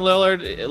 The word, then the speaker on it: Lillard: Damian, how do you pronounce his last name? Lillard? Am I saying that correct? – Damian Lillard, yes, from the Portland Trailblazers.